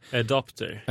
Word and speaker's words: adopter: Jag har ju fått skit för det här på Instagram för att jag uttalar fel när jag säger adapter eller adopter. – Adopter. Ja, jag ska, alltså man ska ju vara en early adopter.